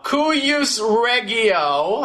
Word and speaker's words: cuius regio: So, cuius regio, I think that it's supposed to be strong G's, not soft G's.